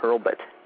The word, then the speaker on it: Hurlbut: And the last name is spelled H-U-R-L-B, as in boy, U-T, Hurlbut. – U-B. – H-U-R-L-B-U-T.